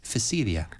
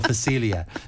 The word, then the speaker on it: Phacelia: So you could, Phacelia. As in easy. – The P is silent. – Oh, it's PH, sorry. – As in swimming. – Oh, okay. – So Phacelia. So look for that plant.